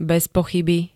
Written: bezpochyby

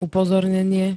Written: upozornenie

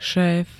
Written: šéf

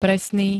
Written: presný

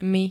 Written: my

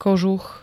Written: kožuch

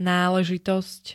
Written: náležitosť

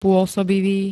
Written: pôsobivý

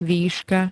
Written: výška

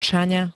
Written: Čaňa

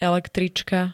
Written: električka

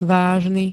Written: vážny

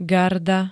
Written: garda

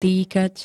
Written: týkať

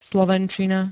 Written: slovenčina

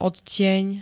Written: odtieň

